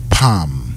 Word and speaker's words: palm: PALM. The L is silent. It's not pronounced.